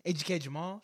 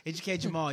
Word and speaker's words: Ejike Jamal: This is AJ, AGK Jamal. Shoot, forgot my name. AGK Jamal. You can find me on Twitter at AGK Jamal. That's spelled E-J-I-K-E-J-A-M-A-L.